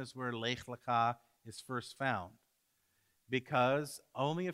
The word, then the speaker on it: Lech Lecha: We're going to pronounce it Lech Lecha.